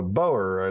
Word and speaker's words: Boer: So, In addition to Boer, I learned. – Yeah, Boer is farmer in Dutch, and they pronounce a boer, but it's that's Dutch for farmer.